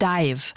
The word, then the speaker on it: DIIV: Und jetzt erstmal neue Musik hier in Laut und Kantig, nämlich von der New Yorker Band Dive. Schreibt man D-I-I-V.